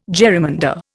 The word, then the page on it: jerrymander: Indian